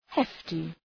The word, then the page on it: hefty: ‘heftı